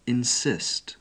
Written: insist

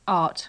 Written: art

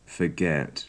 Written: forget

